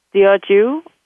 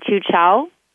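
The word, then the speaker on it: Teochew: It's actually Diochu. And okay. Some people call it Chu Chow.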